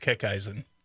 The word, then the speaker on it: Kickeisen: It's pronounced Kickeisen. – Kickeisen. – Yep. – Kickeisen. Rah. – Yeah. – Slightly German.